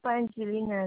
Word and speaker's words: Pangilinan: My last name is Pangilinan, and that's P-A-N. G-I-L-I-N-A-N.